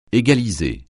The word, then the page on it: égaliser: francais